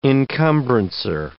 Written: encumbrancer

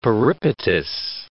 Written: peripatus

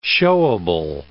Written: showable

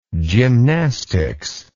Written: gymnastics

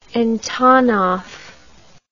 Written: antonov

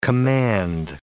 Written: command

